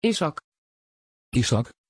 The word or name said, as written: ishaq